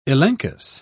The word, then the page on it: elenchus: British English